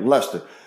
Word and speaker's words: Leicester: And that's right. I said it right. Leicester.